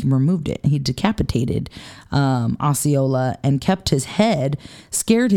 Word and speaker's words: Osceola: Osceola. Yeah. My spelling of this is wrong, but that's how it is pronounced.